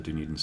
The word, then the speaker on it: Dunedin: We had a group before that used data out of the Dunedin study, and we didn't know how to say it, so we went on Google and clicked the button, and it said Dunedin.